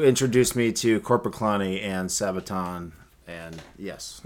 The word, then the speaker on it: Sabaton: Sabaton. How do you say it? – Sabaton.